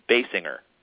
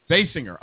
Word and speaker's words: Basinger: I found the actual pronunciation is Basinger. – Basinger. Okay. – Not Basinger or the other eight ways I've pronounced it. Basinger.